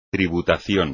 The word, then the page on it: tributación: espagnol